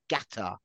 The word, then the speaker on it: Qatar: Ben. It's Qatar. Qatar. Pronounced with a G.